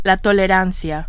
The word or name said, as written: tolerancia